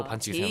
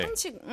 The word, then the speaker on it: 반칙: 칙이 조금 어려울 수도 있을 것 같긴 해요. – 칙. 이게 뭐 칙칙 봄 봄. 마모사 노 댓츠 원잇 봄. – 네, 근데 칙 발음이 좀 어렵네. – 괜찮은 것 같아요. – 반칙. – 반칙. – Like chick. Chick with a chick. 근데 칙이 영어로 있긴 하네. Baby, baby chicken.